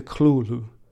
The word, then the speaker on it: Cthulhu: This is called the Cthulhu, which can also be pronounced the Clulu. The writer himself apparently pronounced it a couple of different ways, so I won't feel guilty about almost certainly getting it wrong.